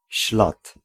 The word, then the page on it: scent: [sent]; /sɛnt/